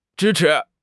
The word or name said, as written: zhi1 chi2